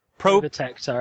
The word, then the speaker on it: Probotector: And, like, just looking at all those weird fucking games, and again, even then seeing their variations, like I said, a Probobector, or I don't know how to pronounce it, Probector? – Probotector, I think it Probotector, is. Probotector, yeah.